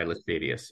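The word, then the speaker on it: Laestadius: There's an awakening movement there, you said, that was led by Lars Levi Lestadius. Am I pronouncing that right? – Lars Levi Lestadius, yeah.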